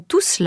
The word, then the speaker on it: tous: On prononce la consonne finale de tous. – Mes amis sont tous là.